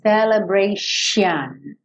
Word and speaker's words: celebration: Escreve com T, mas você vai falar esse T como se fosse um X. Então, esse T, I, O, N vai soar X, I, O, N. Celebration, ok?